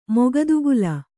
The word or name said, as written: ಮುಕ್ತಿಕಲ್ಲು